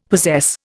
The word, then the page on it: possess: Indian